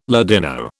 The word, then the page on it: Ladino: British English